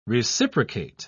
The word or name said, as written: reciprocate